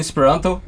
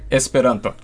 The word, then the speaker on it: Esperanto: In Esperanto, the closest I can get to the standard pronunciation would be Esperanto. But then French Esperanto speakers would be more likely to say Esperanto. And English speakers would say Esperanto. So even the way you pronounce the words, for example, the French would be more likely to put the stress in the last syllable, which is not how it works in Esperanto, but that's how they adapt to what they can do.